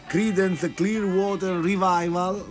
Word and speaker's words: Creedence Clearwater Revival: Questa volta parliamo di altri due gruppi, Creedence Clearwater Revival, l'ho detto bene? – Benissimo, stupendo.